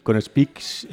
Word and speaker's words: Speexx: Muy bien, punto y seguido, Elena, con Speaks, lo he pronunciado bien hoy, ¿no? – Sí, muy bien.